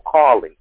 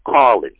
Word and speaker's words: Collins: Is your last name spelled C-A-R-L-E-Y? – No, Collins. C-O-L-L-I-N-S. Michael Collins. – Spell that for me one more time, please. – Yes. C-O-L-L-I-N-S.